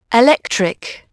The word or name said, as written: electric